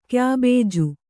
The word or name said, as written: ಕ್ಯಾಬೇಜು